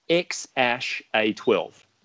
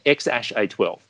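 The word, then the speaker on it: X Æ A-12: And what transpires is, I've learned that it is pronounced X-Ash A12. Okay? X-Ash A12.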